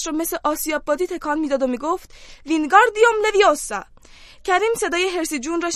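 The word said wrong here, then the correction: Wingardium Leviosa: شنید که با اوقات تلخه میگفت داری اشتباه تلفظ میکنی باید بگی وینگاردیوم لویوسا گارش رو بهتر و کشیده تر تلفظ کن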